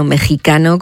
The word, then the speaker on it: mexicano: Bueno, pues por fin está ya disponible y abierta la instalación inmersiva de realidad virtual del cineasta mexicano Alejandro, no mexicano, mexicano, como recuerdan desde allí, se escribe con X, se dice con J.